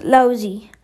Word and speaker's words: Laozi: His name sometimes spelled Laozi too, L-A-O-T-Z-U, but it's pronounced Laozi, was said to be the author of a work called the Tao Te Ching.